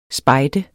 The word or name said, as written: spejder